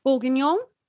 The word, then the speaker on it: bourguignon: A mushroom bourguignon. Am I saying that right? – Bourguignon. – Bourguignon. – Yeah. – I think that's it.